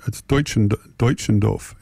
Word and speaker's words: Deutschendorf: So let's just play a little Jonathan Dutchendorf. Bob Middleton tells me how to pronounce it. It's Deutschendorf. Anyway, John Denver.